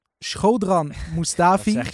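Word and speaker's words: Shkodran Mustafi: Schodran Mustafi. – Dat spreek je perfect uit.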